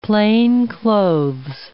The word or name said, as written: plainclothes